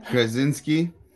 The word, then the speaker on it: Krasinski: Krasinski.